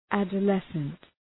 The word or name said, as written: adolescent